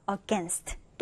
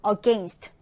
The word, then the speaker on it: against: 자, 이 단어는 발음이 두 가지 가능하네요. against 또는 against 둘 다 가능한데요.